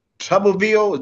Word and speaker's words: Tuberville: And this motherfucker, Tuberville, is that how you say his name? – Yeah.